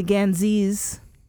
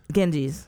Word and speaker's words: Ganges: You know, as they went through the Ganges, right? – The Ganges? – Ganges.